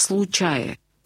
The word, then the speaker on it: случае: Вот это выражение «человек в случае», Лотман настаивает на том, что ударение нужно делать именно на последний слог, да, «случай».